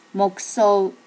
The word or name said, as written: mokuso